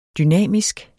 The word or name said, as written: dynamisk